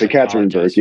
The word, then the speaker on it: Ekaterinburg: which I think is UMMC Ekaterinburg. I'm probably mispronouncing. – No, it's pretty close. Ekaterinburg, yeah.